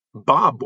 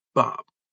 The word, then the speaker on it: barbed: Barbed wire is spelled B-A-R-B-E-D. And in places not dominated by soybeans and corn, it's pronounced barbed, not bob.